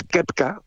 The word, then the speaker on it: Koepka: si, lo dijésemos bien, que es Kepka,